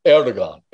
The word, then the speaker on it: Erdogan: Drogan. Is that how I say his name? Turkish guy? – Erdogan. – Erdogan. Yeah.